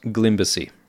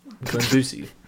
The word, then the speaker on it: Glimbussy: I believe it's pronounced Glimbersy. – Like the romantic composer Debussy. Glimbussy.